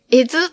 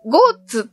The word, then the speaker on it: 江津: 入江の江と津で江津と読むそうです。お昼ご飯を食べたお店で、今日はどこに泊まるのって聞かれて、えずって答えたら、ポカーンとされました。そして少し間を置いて、それ、ゴーツって読むんですよって教えてくれました。